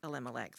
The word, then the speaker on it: Elimelech: Naomi, just family, and Abimelech. Lived in, did I say that right? – Elimelech. – Yeah, E-L.